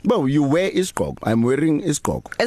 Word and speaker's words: isigqoko: Isigqoko. Isigqoko.